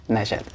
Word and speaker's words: Najat: Najat, okay.